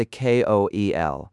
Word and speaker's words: KOEL: Pilots call this the KOEL. And you say it like the name Joel with a K in front.